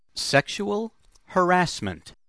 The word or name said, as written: sexual harassment